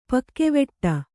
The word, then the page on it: ಪಕ್ಕೆವೆಟ್ಟ: pakkeveṭṭa